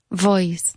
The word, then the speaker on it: voice: More V in voice. – Yeah. How often does he lose his voice? – More V. – Voice.